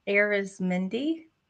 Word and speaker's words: Arizmendi: Yes, Arizmendi. – Arizmendi? Mm-hmm. Okay. Just like it's spelled, but I was like, you know what?